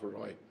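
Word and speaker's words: Ray: He writes Rai, it's pronounced Roy.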